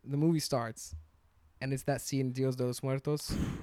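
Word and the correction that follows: Día de los Muertos: Dio de los Muertos. I don't know how to pronounce it. – Dia de los Muertos.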